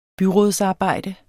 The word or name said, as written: byrådsarbejde